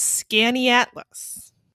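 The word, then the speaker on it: Skaneateles: The way that Amy says it's pronounced is Scaniatlas.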